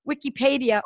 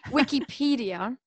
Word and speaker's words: Wikipedia: So another area, as I always say to people when they say, really, this is proven, I don't know, I go to Wikipedia. Oh, excuse me. I mispronounced the word. Wikipedia.